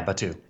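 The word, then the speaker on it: Batuu: Batu. Is that how you say it? – Yeah, Batu.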